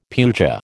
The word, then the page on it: puja: American English